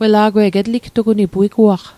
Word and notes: etligtugunit: Mi'gmaq

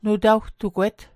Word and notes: nutawtugwet: Mi'gmaq

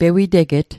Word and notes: pewiteget: Mi'gmaq

